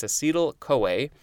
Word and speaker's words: acetyl-CoA: I guess it's pronounced CoA. Yeah, I think that's it. Actually has a pronunciation for that. So it's acetyl-CoA.